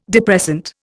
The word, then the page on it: depressant: Indian